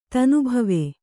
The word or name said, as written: ಧುನಿಬಾವಾ